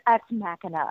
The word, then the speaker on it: Ex Machina: I've been calling it Ex Machina. – I know. That's what I called it too. – And everyone's like, it's Ex Machina. And I'm like, oh, whatever. – Well, then spell it with a K.